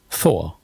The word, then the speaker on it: Thor: Und gerade bei Love and Thunder, wo man ja dann das TH wirklich aussprechen muss, bin ich so, eigentlich müssten wir ja Thor sagen jetzt. Ansonsten ist es wieder gemischt. Thor? Ja. Thor. So heißt er nun im Englischen.